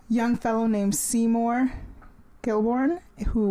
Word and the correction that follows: Krelborn: works at a, uh, Kelborn. I thought it was Kilborn. – Krelborn. – Is that how you say it?